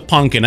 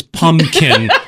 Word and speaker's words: pumpkin: Everybody was a Punkin. And I didn't have the heart to tell him, Dad, you're mispronounced. It's Pumpkin. There's an M in there.